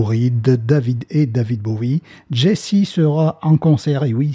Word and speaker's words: Gyasi: compositeur et sensation glam rock Jesse, ça s'écrit G-Y-A-S-I, vient de sortir un nouveau single troquant son instrument préféré, la guitare, contre le piano.